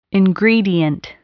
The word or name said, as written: ingredient